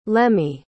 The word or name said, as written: lemmie